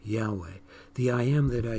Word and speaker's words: Yahweh: And the word Lord, usually, almost always, but not completely, is translated in the original Hebrew as Yahweh. Some will use the pronunciation Jehovah, but that's not as accurate. Yahweh.